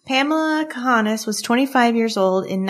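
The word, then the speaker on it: Cahanes: Is it Kahanis? Okay. – It's C-A-H-A-N-E-S. I've heard some people pronounce it Kahanis. I feel like Kahanis is, that's just how I would say it.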